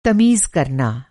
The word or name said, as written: तमीज़